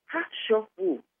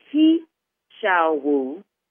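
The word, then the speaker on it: He Shou Wu: It's called Ha-Sho-Wu. I know that sounds crazy. Ha-Sho-Wu. And it's spelled H-E, second word, S-H-O-U, third word, W-U. So it looks like He-Shao-Wu, but it's Ha-Sho-Wu.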